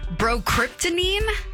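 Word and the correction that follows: Bromocriptine: Bromocryptine. That's it.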